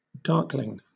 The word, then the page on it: darkling: British English